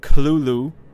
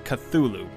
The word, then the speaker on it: Cthulhu: Lovecraft himself gave several differing pronunciations of the creature's name throughout his life, including Klulu, with a heavy guttural first syllable. The most common pronunciation, however, is Cthulhu, popularized much later after Lovecraft's death.